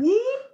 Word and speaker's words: Whoop: Whoop? – Like that.